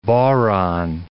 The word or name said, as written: bodhran